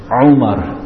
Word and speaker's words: Umar: The ayin, it comes from the middle of the throat. Not like English. What do they write? They write Omar, like this, right? It's not Omar. Omar is Umar.